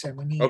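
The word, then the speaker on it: Samunyai: And you have Vanessa Simunia. – Yes, that's how you pronounce Simunia.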